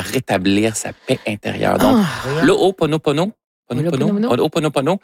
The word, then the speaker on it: Ho'oponopono: Non, c'est une philosophie hawaïenne de, je vais le prononcer comme il faut, de Ho'oponopono. Ho'oponopono.